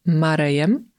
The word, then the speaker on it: Murray: I tu myślę warto zwrócić uwagę na wymowę tych dwóch nazwisk, bo my po polsku nazywamy tego aktora Billem Marejem, mówimy o nim Marej, no a Andy McDowell to właściwie chyba wiadomo.